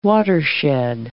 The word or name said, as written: watershed